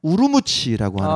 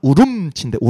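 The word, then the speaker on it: Ürümqi: 그 위구르 말은 우름치인데 우름치. – 우름치. – 우름치. 네. 우름치. 근데 이제 중국어 발음만 우르무치라고 하는데 우름치. 네.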